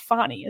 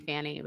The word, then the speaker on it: Fani: It's pronounced Fonny, isn't it? – Oh, I've been saying Fanny, but I know I have too.